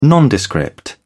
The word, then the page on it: nondescript: British English